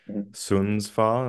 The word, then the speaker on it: Sundsvall: And speaking of where you're at now, this is your second season in the Swedish second tier with Sundsvall. Am I pronouncing that correctly? – Yeah, seems well, yeah.